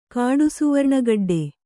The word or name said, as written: ಕಾಡುಸುವರ್ಣಗಡ್ಡೆ